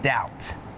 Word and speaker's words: doubt: And some examples with a silent B are lamb, thumb, and doubt.